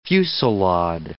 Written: fusillade

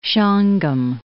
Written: Shawangunk Mountains

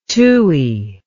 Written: tui

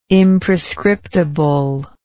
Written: imprescriptible

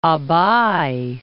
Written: Abbai

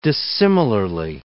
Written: dissimilarly

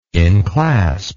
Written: inclasp

